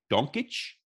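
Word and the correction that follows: Jokić: Oh, Jokic. Good one.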